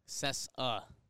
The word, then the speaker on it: Cessa: Kike. – Cessa. – Cessa. – Is the pronunciation.